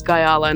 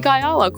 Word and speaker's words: Guyala: Always gonna say my name's Guyala, and I'm still learning now, you know, to this day.